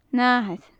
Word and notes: nathair: gaelic

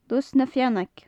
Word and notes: lus na feannaig: gaelic

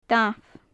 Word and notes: damh: gaelic

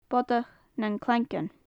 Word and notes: bodach nan claigeann: gaelic

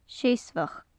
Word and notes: seasmhach: gaelic

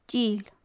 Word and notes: dile: gaelic

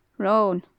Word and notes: ron: gaelic

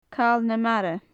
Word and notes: cal na mara: gaelic

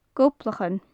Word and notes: gobhlachan: gaelic

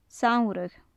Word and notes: samhradh: gaelic

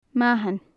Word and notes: mathan: gaelic

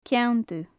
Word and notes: ceann dubh: gaelic

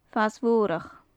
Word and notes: fas-mhorachd: gaelic

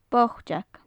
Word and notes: boiteag: gaelic

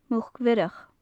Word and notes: muc-bhiorach: gaelic

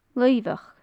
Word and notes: luibheach: gaelic

